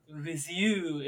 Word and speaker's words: Wiseau: I don't know how it's pronounced. – Wiseau, if you're French, I guess.